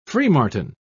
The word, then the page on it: freemartin: British English